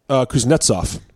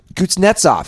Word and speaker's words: Kuznetsov: How do you pronounce this guy's name? Kutsunov? – Kuznetsov. – Kuznetsov.